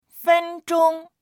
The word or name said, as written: fen1zhong1